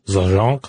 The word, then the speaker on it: Zajonc: It says that in 1989, Robert Zajonc, I believe. I don't know how to pronounce that name. Z-A-J-O-N-C. – Zajonc.